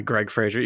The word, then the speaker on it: Fraser: is it Fraser? Is that how you pronounce his last name? – Yeah, Greg Fraser.